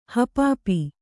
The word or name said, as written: ಹಪಾಪಿ